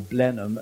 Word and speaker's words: Blenheim: and he ultimately vanquishes them in the Battle of Blenheim, or Blenheim, as we pronounce it here.